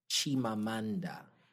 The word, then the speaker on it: Chimamanda: So it's Chimamanda.